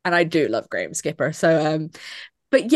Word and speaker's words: Graham Skipper: Is that his name? I think I'm saying his name wrong. I'm going to cut this out anyway. Yeah, it is Graham Skipper. Thank you.